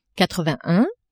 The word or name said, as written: quatre-vingt-un